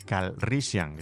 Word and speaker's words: Calrissian: Y la C, Lando Calrissian.